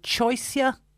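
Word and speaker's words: Choisya: A golden, is it choicea? – Yeah, you're spot on.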